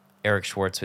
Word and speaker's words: Eric Schwartz: And then Instagram, Eric Schwartz with three E's, EericSchwartz. It's pronounced Eric Schwartz.